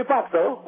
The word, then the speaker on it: pazzo: Means you're crazy, and "pazzo" is spelled P-A-Z-Z-O, like pizza, pazzo.